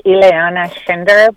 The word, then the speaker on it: Ileana Schinder: Yes, my name is Ileana Schinder, but yeah, you're pronouncing it very, very well.